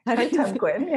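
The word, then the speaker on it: Kartemquin: the Hulu Kartemkin? – Kartemkin, yes.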